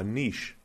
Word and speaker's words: niche: I appreciate that you used the correct pronunciation. – Well, Seth started it, and he's, non-American. – Right. – So he started calling it niche.